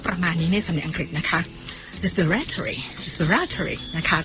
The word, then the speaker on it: respiratory: คำศัพท์ที่เกี่ยวข้องกับการหายใจภาษาอังกฤษบอกว่า relating to breathing เนี่ยนะคะเราใช้คำนี้ค่ะมาดูตัวสะกดก่อนนะคะ R E S P I R A T O R Y